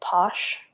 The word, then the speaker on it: pox: And they pass around a liquor, which is from Chiapas, and it's called Posh, but it's spelled P-O-X.